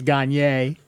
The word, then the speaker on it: Gagné: And Marcel Gagné, that's French, so although it's pronounced Gagné. It's spelled Gagné, it's pronounced Gagné.